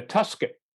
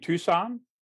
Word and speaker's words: Tucson: And the woman says, well, we can put you in a Tuscan. And the whole thing kind of unrolls with him going, you mean Tucson? She goes, is that how you say it? He goes, yeah.